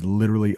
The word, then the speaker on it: literally: That's a D sound, by the way. Did you hear how I said that? Literally. A lot of times in English, that T, it becomes a really soft T that sounds like a D.